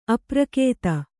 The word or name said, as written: ಅಪ್ರಕೇತ